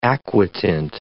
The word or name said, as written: aquatint